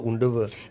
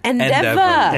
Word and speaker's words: Endeavor: Am I pronouncing it right? Endeavor or Undeavor? – Endeavor. – Endeavor. Okay, I think I got it right.